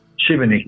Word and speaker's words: Šibenik: So your first professional club was Međimurje, and then is it pronounced Šibenik? – Šibenik, yeah.